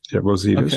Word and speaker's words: Rosidis: And that's by Zafiris Rosidis. I don't know if I said that correctly. – Yes, Rosidis, okay. Yes.